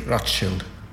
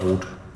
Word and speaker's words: Rothschild: I am aware that almost everywhere in the world it's spelled Rothschild, but here in Italy and in Naples we always say Rothschild. – Rothschild. Rothschild. Like in German. – Yes, as it should be. – But not Rot. – Rothschild.